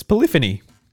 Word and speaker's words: Polyphony: Yeah, it's Polyphony. – It's Polyphony. – P-O-L-Y-P-H-O-N-Y. Polyphony. – It's Polyphony. – Well, I'm staying well out of this, which is why I'm renowned for pronouncing things wrong. – Oh, well, I can't talk about pronunciation, but I know it's not Polyphony.